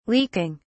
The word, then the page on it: leaking: British English